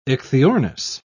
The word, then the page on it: ichthyornis: British English